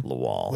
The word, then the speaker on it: Lawal: How do you say this guy's name? – Lawal. – What's up? – King Mo Lawal.